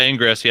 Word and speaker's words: Ingress: Yeah, Ingress.